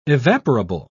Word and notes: evaporable: British English

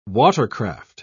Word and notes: watercraft: British English

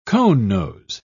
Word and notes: conenose: British English

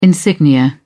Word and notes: insignia: British English

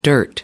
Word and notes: dirt: American English

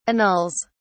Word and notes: annuls: British English

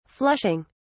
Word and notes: flushing: British English

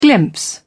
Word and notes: glimpse: British English

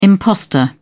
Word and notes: impostor: British English

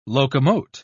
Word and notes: locomote: British English

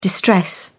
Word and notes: distress: British English

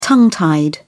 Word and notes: tongue tied: British English

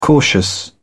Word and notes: cautious: British English

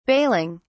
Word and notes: bailing: British English

